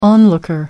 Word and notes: onlooker: American English

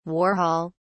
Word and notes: warhol: British English

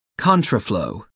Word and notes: contraflow: American English

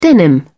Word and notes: denim: British English

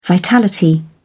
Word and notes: vitality: British English

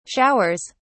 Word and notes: showers: British English

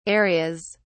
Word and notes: areas: British English

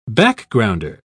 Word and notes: backgrounder: British English

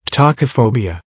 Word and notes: tachophobia: American English

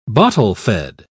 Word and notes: bottle-fed: British English

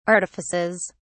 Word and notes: artifices: British English

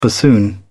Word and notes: bassoon: British English

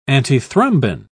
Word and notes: antithrombin: British English

